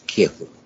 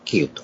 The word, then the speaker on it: キーウ: キエフというところをキーウと、読む、発音するというように、ウクライナ語ということで言いますが、ロシア語、ウクライナ語は、綴りもほとんど非常によく似ていて、発音も本当によく似ている。